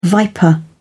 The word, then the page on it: viper: British English